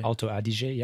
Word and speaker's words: Alto Adige: Yeah, Alto Adige, yep.